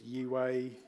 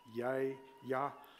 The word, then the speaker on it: Yahweh: suggested that it should be an inhale and an exhale. So it's Yahweh. Breathe in, breathe out.